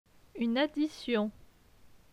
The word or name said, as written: Une addition